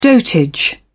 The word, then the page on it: dotage: British English